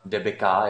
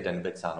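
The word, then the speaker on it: Dębica: že čeští motoristé říkají pneumatikám Debika, jako kdyby to bylo anglické, a přitom ono to je správně polské Denbica.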